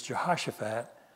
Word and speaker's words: Jehoshaphat: and he would always say, jumping Jehoshaphat, you know. But the correct pronunciation is Jehoshaphat.